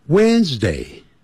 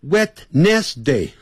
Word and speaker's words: Wednesday: Wednesday, Wednesday, when 那个 W D 那个 D 不念出来的。 When 更不要念成 Wednesday 哈，不是按照那个字母这样来念啊。不要自己发明叫 Wednesday，不是这样子。